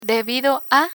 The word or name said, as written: debido a